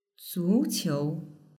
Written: zuqiu

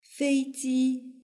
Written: feiji

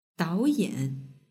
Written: daoyan

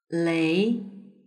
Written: lei

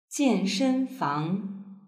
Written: jianshenfang

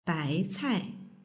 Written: baicai

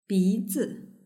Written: bizi

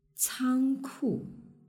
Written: cangku

